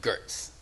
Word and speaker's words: Geertz: And when I was introduced to him, I put out my hand and I said, and he goes, Cliff Geertz. And I went, Geertz? You pronounce it Geertz? So that was my first lesson in how to say his name.